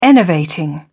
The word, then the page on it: enervating: British English